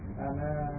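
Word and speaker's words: ana (أنا): Ana Pakistaniun. – Ya. – Ana min Pakistana. – Ya. Ana ini membacanya pendek ya, ana. Bukan ana, tapi pendek, ana. Jadi alifnya yang akhir itu tidak diucapkan ya.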